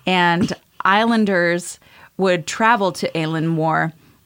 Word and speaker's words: Eilean Mòr: According to Paranormal Guide, so I had to look up how to pronounce this because to me, it looks like Eileen, and immediately I launch into like, come on, Eileen. But it's not Eileen Moore, it's Aileen Moore.